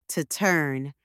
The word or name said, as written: to turn